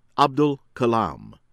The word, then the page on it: A.p.j. Abdul Kalam: American English